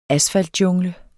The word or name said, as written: asfaltjungle